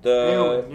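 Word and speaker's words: Éowyn: But it's Eowyn? – Eowyn, yeah.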